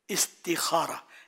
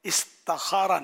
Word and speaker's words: استخارہ: استخارہ، اس کا صحیح تلفظ استخارہ، استخارہ نہیں ہے۔